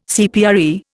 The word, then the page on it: CPRE: Indian